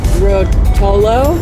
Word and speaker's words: Ruotolo: We are doing the Surrender Novena with Father Delindo Ruotoli. I didn't say that right, his last name, but it's precious to me. – I think it's Ruotolo, is that maybe.